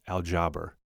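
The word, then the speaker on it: aljabr (الجبر): When you look at the root of the word algebra, it comes from an old Arabic word pronounced aljabr.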